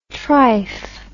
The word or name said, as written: trife